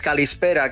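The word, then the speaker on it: calispera (καλησπέρα): Mira, cuando te digo buenas tardes, calispera, qué sonido más bonito, y es súper fácil de decir, calispera para caló.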